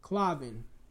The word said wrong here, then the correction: Colvin: Pronounce that. – Colvin.